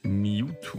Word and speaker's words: Mewtu: Mewtwo. – Man schreibt es M-E-W-T-U. Mewtwo. – Mewtwo.